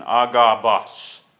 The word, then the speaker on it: Agabus: "And as we tarried there many days, there came down from Judea a certain prophet named Agabus." That's the correct pronunciation, Agabus.